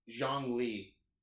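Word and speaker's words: Zhongli: No, it's Zhang Li. He is Zhang. Oh, me. – So it's spelled Zhong, and then Li, but he He's an Earth character.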